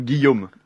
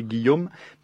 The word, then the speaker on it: Guillaume: Sería, tipo, es Guillaume. Tipo, para pronunciar, tipo, un guión de cinema, pero om al final, así que Guillaume. Pero no se escribe así, porque en francés no se escribe como se pronuncia.